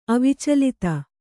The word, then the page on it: ಅವಿಚಲಿತ: kannada